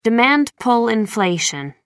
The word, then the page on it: demand-pull inflation: American English